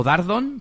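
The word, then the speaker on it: Dardon: Bueno, y nos vamos con Luis Dardón, que nos decía, o Dardón, porque no lleva tilde, dice, saludos desde Guatemala.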